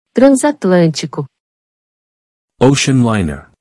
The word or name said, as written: Transatlantico